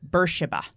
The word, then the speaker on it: Beersheba: Beersheba.